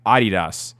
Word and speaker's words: Adidas: Saying Adidas. – And no, the brand ambassadors are now saying like Adidas. Like that's how they're wanting them to, because that's how it's pronounced.